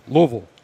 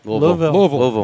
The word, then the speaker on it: Louisville: It's not Louisville. It's Louisville. – Louisville. – You got to pronounce it correctly.